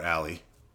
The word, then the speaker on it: Ally: Yeah, her name is, she's Allie, but it's spelled A-L-L-Y.